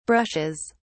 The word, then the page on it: brushes: British English